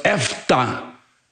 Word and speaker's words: efeta: Esta palabra que en arameo no se dice efeta, efeta, decimos en español, pero la palabra como se debe de pronunciar en el idioma arameo es efta.